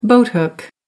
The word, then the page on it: boathook: American English